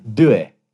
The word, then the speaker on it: due: How do you say it in Italian? – Due. – Oh, that's better.